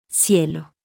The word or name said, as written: Cielo